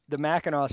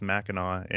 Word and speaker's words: Mackinac: You got the Mackinac Center pronunciation correct, which is pretty rare. – Well, thank you. I'm actually I'm a pretty big Johnny Cash fan, and I think he says Mackinac in one of his songs.